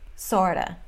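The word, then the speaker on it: sort of: E a pronúncia não é kind of. Não tem pausa. E nem fala of v, no final. Kinda, sorta, outa.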